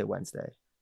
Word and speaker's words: Wednesday: Do you say the N in Wednesday? Wednesday? – You mean the D? – Yeah, no. The D. – Wednesday? – No, it's like Wednesday. – Well, yeah, it's spelled Wednesday. Yeah. I don't say that. I say Wednesday. I switch the N and the D. – Yeah, Wednesday. – Wednesday. Because Wednesday makes no sense. Too many syllables.